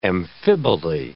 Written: amphiboly

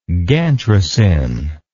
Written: Gantrisin

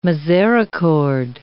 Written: misericord

